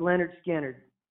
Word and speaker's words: Lynyrd Skynyrd: Uh, pronounced Leonard Skinner. Mm-hmm. – mm-hmm. – I should know this coming from Florida. – pronounced Leonard Skinner.